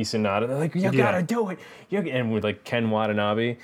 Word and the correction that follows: Watanabe: Why did I say his name like that? That was weird. Watanabe.